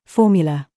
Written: THOUGHT

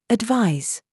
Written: ZOOS

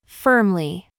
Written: FIFE

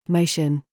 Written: GOAT

